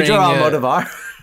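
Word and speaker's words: Almodóvar: Pedro Almodovar. I'm sorry. – Did I say it wrong? – No. Actually, I think you got it.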